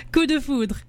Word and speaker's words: coup de foudre: And a local Vancouver artist with a new single out called Coup de Fond. Did I say that right? – Coup de foudre. – So close. I don't know why I want to say frond.